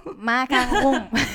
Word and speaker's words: 马卡龙: 这个发音一定要发清楚，因为你说这个马卡洪，我好几次都听成了马克洪，我就觉得你是在说马克龙。 – 马卡龙，马卡龙，对。 – 我想问的是，这个马克呃，这个马卡龙哈，都说好吃，我这也差点被绕进去。